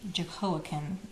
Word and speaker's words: Jehoiakim: And I introduced King Jehoiakim is how the English pronunciation, who's often, he has several other names, Jeconiahu and also Yeconia.